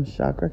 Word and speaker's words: Chakra: I am Chakra Khan. It's spelled Chakra, not Chaka, although that is my girl, and I've gotten to speak with her uh, about this, and she thinks it's so hilarious.